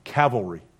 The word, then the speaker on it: Cavalry: That's the Calvary. I said it wrong. Cavalry. I always mix those two up.